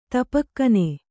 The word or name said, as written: ತಪಕ್ಕನೆ